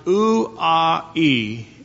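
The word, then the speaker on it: ou-a-e (οὐαί): The Greek word is roughly pronounced ou-a-e, and you get the idea.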